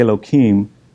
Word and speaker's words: Elohim: I'm going to say Elohim, but it's the proper pronunciation. You put an H in there or a hey, and it's instead of the K.